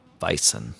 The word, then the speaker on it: Weizen: Weizen. – Sorry. – The W is always pronounced as a V.